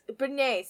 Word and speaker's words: beignets: Beignets. But there's beignets, thank you.